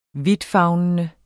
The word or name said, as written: vidtfavnende